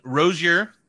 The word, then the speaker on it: Rozier: And another co-host, we've got Tiffany Rozier.